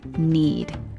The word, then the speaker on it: knead: Take care with the silent letters in the verb knead, which is spelled K-N-E-A-D.